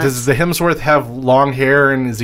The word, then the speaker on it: Hemsworth: And in this metal world is at one of the Helmsworths. Is that how you say it? Helmsworth? Like, Miley Cyrus's? Not her husband, but a Hemsworth. Oh, yeah. – Same difference. It's a metal world with a Hemsworth.